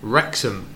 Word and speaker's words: Wrexham: Wrexham. Yeah, the W is silent, but Wrexham. – Okay, so Wrexham, how is it spelt? – W-R-E-X-H-A-M.